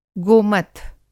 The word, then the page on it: गोमथ: Hindi